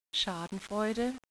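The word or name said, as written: Schadenfreude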